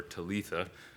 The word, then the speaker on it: Talitha: Talitha or Talitha, as the Aramaic would be pronounced.